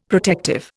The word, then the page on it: protective: Indian